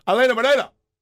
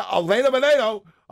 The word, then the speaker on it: Helena Moreno: Or excuse me, I don't want to sound like a gringo when I say her name. Helena Moreno. Yeah, Helena Moreno crafted a resolution on NFL refereeing.